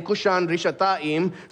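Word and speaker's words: Cushan-rishathaim: In Hebrew, Mesopotamia there is the longer name is given, which is Aram Naharaim, which rhymes with his name, Cushan Rishathaim.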